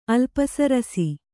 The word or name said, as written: ಅಲ್ಪಸರಸಿ